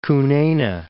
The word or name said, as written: Kunene